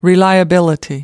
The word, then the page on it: reliability: American English